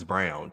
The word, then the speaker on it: Braun: It's, it's pronounced as Brown though.